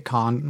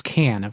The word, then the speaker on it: Cannes: It won the Grand Prix at Cannes, which, damn it, I pronounced it wrong. It's Cannes. For the last time, everybody, it's Cannes.